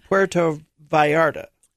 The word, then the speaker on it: Puerto Vallarta: The first stop is in Puerto Vallarta.